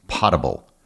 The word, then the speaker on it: potable: I just got done with the Banana Republic episode from 2021, and you pronounced the word potable incorrectly. Many people have been told a fable that the word means good enough for a pot, when in actuality it's pronounced potable.